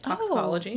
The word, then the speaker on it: toxicology: to be incredibly wise for his time and was even referred to as the father of toxology. Is that how you say it? Toxicology? – Oh, yeah.